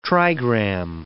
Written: trigram

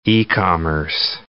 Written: e-commerce

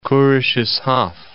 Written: Kurisches Haff

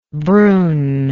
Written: Broun

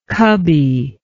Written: cubby

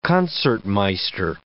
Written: concertmeister

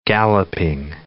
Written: galloping

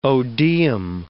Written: odeum

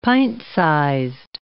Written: pint-sized

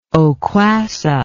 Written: oquassa

